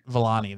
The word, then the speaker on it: Iman Vellani: It's Iman Vellani. – Iman Vellani. – Vellani, there you go.